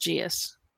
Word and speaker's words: geas: And it's spelled G-E-I-S. But G-E-A-S is a different dialect. And it is pronounced geus.